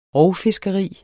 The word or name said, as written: rovfiskeri